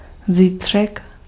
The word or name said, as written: tomorrow